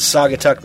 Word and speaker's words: Saugatuck: October 25th, they're going to be at the Boathouse in Saugatuck, Michigan. Rhymes with fuck.